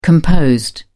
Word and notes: composed: British English

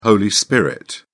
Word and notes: holy spirit: British English

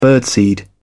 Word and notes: birdseed: British English

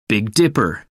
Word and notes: big dipper: American English

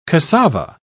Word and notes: casava: British English

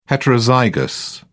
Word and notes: heterozygous: British English

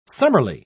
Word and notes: summerly: British English

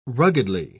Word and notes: ruggedly: British English

